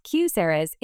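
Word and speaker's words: QSEHRA: KSERAs, pronounced QSERAs in the employee benefits industry, are a tax-advantaged way for employers to help employees pay for healthcare expenses.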